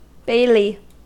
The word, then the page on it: bailey: American English